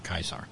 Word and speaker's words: Caesar: In Germany, he became the Kaiser, which is actually closer to the actual pronunciation of C-A-E-S-A-R, Kaiser.